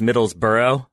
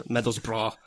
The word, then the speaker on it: Middlesbrough: And Graham, just before we get angry tweets, we should note it's pronounced Middlesbrough. That's how everyone likes to pronounce it.